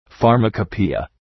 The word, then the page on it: pharmacopoeia: ,fɑ:rməkə’pi:ə